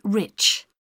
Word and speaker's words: Rich: It's Mrs. Rich. – How do you spell that? – R-I-C-H.